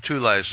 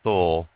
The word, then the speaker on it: Thule: So all of you Christians out there, try to explain away the Thule Society, and that's spelled T-H-U-L-E. Some people say Thule, but I've heard people who speak German call it Thule.